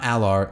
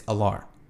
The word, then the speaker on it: Allar: It's not Allar, it's Allar. So my apologies for that one.